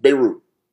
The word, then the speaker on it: Beirut: Beirut.